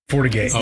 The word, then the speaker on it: Fortigate: Fortigate. – Fortigate.